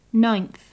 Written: ninth

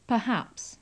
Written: perhaps

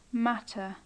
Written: matter

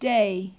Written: day

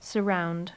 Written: surround

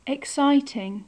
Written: exciting

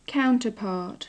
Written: counterpart

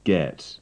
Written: get

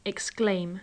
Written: exclaim

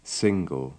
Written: single

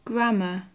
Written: grammar

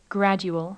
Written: gradual

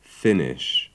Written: finish

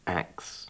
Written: axe

